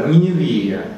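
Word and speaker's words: Ни. Не. Вия (נינוה): Йод не произносится. Ни. – Ни. – Йод обозначает долгий и. – Ни. Не. – Угу. – Ни. – Ни. Не. – Ва. – В. Ни. А, В. Ни. Не. В. Вот так. Ни. Не. В. Это... А? – Ни. Не. – Да. Ни. Не. Вия. Я бы сказал.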